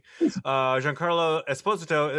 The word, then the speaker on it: Giancarlo Esposito: Vegas has odds that Giancarlo Esposito, I learned how to say his name correctly, Giancarlo Esposito is to play Dr. Doom in the announced new Fantastic Four movie.